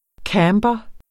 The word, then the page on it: camber: ˈkæːmbʌ; ˈkɑmˀbʌ